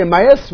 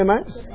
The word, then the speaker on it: Emmaus: I say Emmaus. Would you say Emmaus? The mouse. Okay. All right. It did sound like a mouse, and I just wasn't sure if that was an American pronunciation.